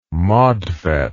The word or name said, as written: modfet